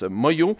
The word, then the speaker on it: Manglo: Ça s'écrit Manglo, mais ça se prononce Moyo.